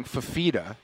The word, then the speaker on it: Fifita: The Arizona quarterback, which I always pronounce his name incorrectly Fafita.